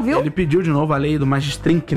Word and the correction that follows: Magnitsky: né? Como é que fala isso? – O quê? – Magnitsky. Magnitsky. Ele pediu de novo a lei Magnitsky para o Xandão.